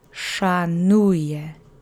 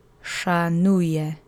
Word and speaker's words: Szanuję: You'll need your duck face for this to make the sh sound, and the accent is on the middle syllable. So repeat after me 3 times. Szanuję. Szanuję. Szanuję.